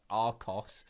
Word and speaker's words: Archos: Just in case you don't, it's spelled ARCHOS. That's Arcos.